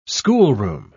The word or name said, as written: schoolroom